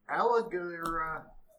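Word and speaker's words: Alagarthas: His name is Alagirathus.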